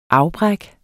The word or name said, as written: afbræk